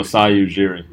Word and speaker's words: Masai Ujiri: Oh, Masai Ujiri.